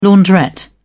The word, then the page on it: launderette: British English